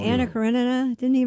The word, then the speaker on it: Karenina: Oh, no, you said it right. It's Karenina. You're right. – Thank you. – Karenina.